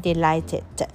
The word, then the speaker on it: delighted: delighted 那注意 delight 加上 ed D-E-L-I G-H 不发音 T 然后加上